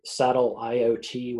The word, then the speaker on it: Sateliot: Um, there's an interesting, uh, there are a number of companies in Europe, um, uh, OQ Technology, um, Sateliot, which is spelled like it sounds, take satellite and IoT and mash them together and you get the name of the company.